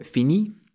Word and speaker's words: finis: So if you just remove the R from finir, we're left with fini, spelt F-I-N-I, and that actually means finished. So that's the past participle of the verb. So we add an S to make it the present tense. It's pronounced the same though. So je finis means I finish.